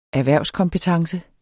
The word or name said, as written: erhvervskompetence